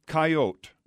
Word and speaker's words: Coyote: For lack of a better name, he called him Coyote, not pronouncing the E.